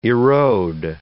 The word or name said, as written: erode